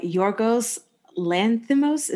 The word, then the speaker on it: Yorgos Lanthimos: Directed by Yorgos Lanthimos, is that how you pronounce it, Jamie? – Mm-hmm.